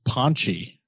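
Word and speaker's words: pączki: The name on the package was like P-A-C-Z-K-I, but it wasn't a normal A. It has like a little tail. And she said her friend told her they were pronounced Ponchi. And I was like, oh, I see what's going on here. There's one of these nasal vowels.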